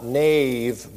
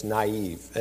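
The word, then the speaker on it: naïve: And if you look at some good newspapers and they write the word naive, it has two dots over the I instead of the one. And that's because you want to pronounce the I sound as well as the A sound.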